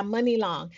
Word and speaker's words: Muni Long: She put her shit out as this new artist, Money Love. I think it's Money Long or Love. I'm saying it wrong. Forgive me. This whole time, I think it's Money Long. MUNI. Yeah, Money Long.